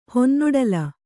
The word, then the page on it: ಹೊನ್ನೊಡಲ: honnoḍala